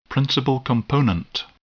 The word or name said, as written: principal component